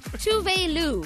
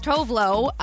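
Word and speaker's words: Tove Lo: Tuvalu. So the internet was like, oh my God, this is crazy. That's clearly not how you say her name. To which, who we know as Tovlo, put on her Twitter, this is not a secret.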